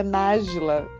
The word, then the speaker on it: Nájila: Resumidamente, então eu sou a Nájila, pessoal é Nájila. E é assim como se, se fala, é como se escreve, e é isso.